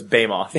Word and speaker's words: behemoth: Not unlike JVP, who's behemoth. – No, it's behemoth.